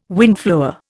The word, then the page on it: windflower: Indian